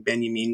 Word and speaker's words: Benjamin: Um, I always say his name Benjamin because that's how it's supposed to be said because he's German or whatever.